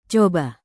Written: coba

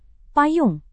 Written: payung

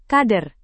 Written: kader

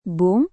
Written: bung

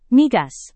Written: migas

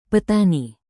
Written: petani

